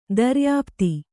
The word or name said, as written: ದರ್ಯಾಃಪ್ತು